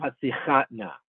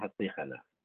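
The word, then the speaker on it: Hatzlicha Na: So what you should say, you read the words in the Siddur, you would say, Ana Hashem Hoshia Na, and Ana Hashem Hatzichat Na. Stress the second to last syllable in Hoshia Na, stress the last syllable in Hatzichat Na.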